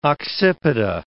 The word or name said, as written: occipita